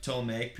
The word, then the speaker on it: Ptolemaic: It is believed to be dated from the early Ptolemaic. – Silent P. – Yep. – Ptolemaic period, which began in 323 BC after the death of Alexander the Great.